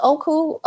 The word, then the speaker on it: Okko: So I threw out a bunch of panties and replaced them with new sustainable panties from Nikki and this other brand called, I believe it's pronounced Oku, O-K-K-O. – Wait a minute. What's Nikki? – N-I-C-K-I-N-I-C-K-Y? K-N-I-C-K-E-Y.